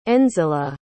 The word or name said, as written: Nzila